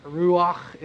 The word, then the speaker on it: Ruach: Ruach is how it actually is pronounced.